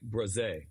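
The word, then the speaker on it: Bresee: So only reason I know it, yeah, because it's pretty it's like bruh, like Braze. – Oh. – So really? Yes. So Brian Braze is how you really truly say it.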